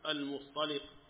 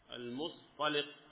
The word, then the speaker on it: المصطلق: ويقال لها المريسيع، وهي نفس غزوة بني المصطلق، والمصطلق أو المصطلق بالفتحة ضمنها بضم الميم وسكون الطاء وسكون الصاد وفتح الطاء وكسر اللام، المصطلق المصطلق هكذا تنطق،